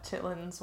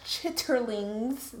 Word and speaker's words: chitterlings: Before you told me what it was, I did not know what chitlins were. And also, that is not spelled chitlins. – It's pronounced chitterlings, but it's short for chitlins.